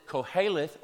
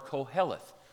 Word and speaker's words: Koheleth: And I understand there's actually two ways to say that. Just getting it out there, Bill, because you know you might say it the right way next week, but it's actually okay to pronounce it Koheleth or Koheleth. So I'm going with Koheleth.